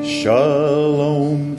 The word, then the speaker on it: shalom: The word is shalom. Can you say that with me? Shalom. It's spelled S-H-A-L-O-M.